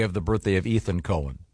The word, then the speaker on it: Cohen: Cohen. Yeah, you. – This gets confusing because... – No, not when it's spelled C-O-H-E-N. – Yeah, it's definitely Cohen.